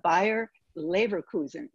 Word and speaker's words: Bayer Leverkusen: It's Bayer Leverkusen. Is that correct? – Perfect.